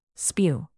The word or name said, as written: spew